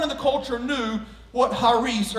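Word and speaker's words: charis (χάρις): In the Greek, the word grace is the word charis, C-H-A-R-I-S. The C is silent in Greek, and it's actually pronounced charis.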